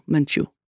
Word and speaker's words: Menchu: "I am Father Arturo Menchu," accent on the second syllable.